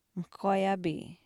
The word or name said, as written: mihkwaayaapii